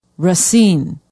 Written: racine

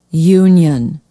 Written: union

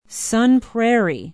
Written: sun prairie

